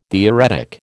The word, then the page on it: theoretic: American English